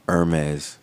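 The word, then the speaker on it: Hermès: The H is silent. – It is. Hermes.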